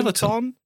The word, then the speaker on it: Peloton: Is it Peloton? Peloton? – Peloton.